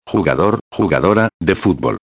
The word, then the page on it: jugador/a de futbol: espanhol